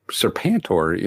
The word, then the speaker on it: Serpentor: I'm not sure it's pronounced that way, but no, yeah, it's Serpentor. You know, I love Serpentor. I like. – There's no A in Serpentor.